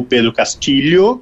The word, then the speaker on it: Pedro Castillo: que tinha um presidente chamado Pedro Castillo, ou Pedro Castillo, para quem prefere a pronúncia em espanhol, tentou o que está sendo chamado como um golpe de Estado.